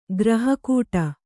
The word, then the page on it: ಗ್ರಹಕೂಟ: graha kūṭa